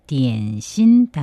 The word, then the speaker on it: 点心: 간식, 点心, 点心. 한자로는 한국에서 말하는 점심과 똑같습니다. 点心, 다만 우리는 오찬을 뜻하는 게 아니라 간식을 뜻하고 있습니다.